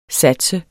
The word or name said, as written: sats